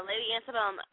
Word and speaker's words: Lady Antebellum: Lady Antebellum. Am I saying that right? Lady Antebellum? – Yeah, Lady Antebellum, I Need You Tonight or something.